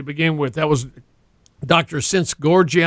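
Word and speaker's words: Szent-Györgyi: Well, it's Dr. St. Gorgi is how they pronounce right.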